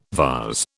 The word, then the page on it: vase: British English